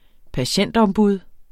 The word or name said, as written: patientombud